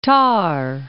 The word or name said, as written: Thar Desert